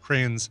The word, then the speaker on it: crayons: I have to pronounce that O. – Yeah, crayons. You have to, because it's a damn O. – Yes, it's put there for a reason. Yeah. It's not C-R-A-Y-N-S.